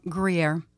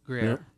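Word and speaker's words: Gruyère: Gruyere. – Gruyere.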